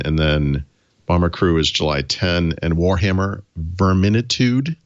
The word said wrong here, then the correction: Vermintide: Vermintide. So it's Vermintide, dude.